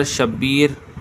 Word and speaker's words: شبیر: ان اسماء کا تلفظ لغت کی مشہور کتاب قاموس میں ہے کہ شبر بقمہ کے وزن پر، شبیر